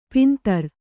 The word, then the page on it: ಪಂದರ: pandara